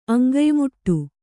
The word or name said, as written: ಅಂಗಯ್ಮುಟ್ಟು